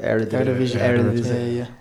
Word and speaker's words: Eredivisie: Eredivisie.